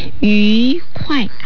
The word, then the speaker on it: 愉快: 愉快、発音ですけれども、愉快、口を丸めて発音します。二世、快、 K-U-A-I、ユキヨン四世です。